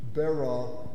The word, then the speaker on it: Bera: And by the way, the king of Sodom, his name is Beron, and the Ra, it's two syllables, the Ra syllable, the second syllable, is the Hebrew word for evil, okay? Bera, the evil.